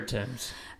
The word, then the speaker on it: Thames: I always say it wrong. So. – It's the River Thames.